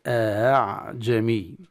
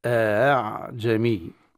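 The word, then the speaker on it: أأعجمي: يقرأ بتسهيل الهمزة الثانية، يعني هكذا: أعجمي، أعجمي.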